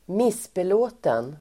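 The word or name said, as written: missbelåten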